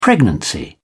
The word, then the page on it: pregnancy: British English